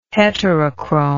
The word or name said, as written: heterochrome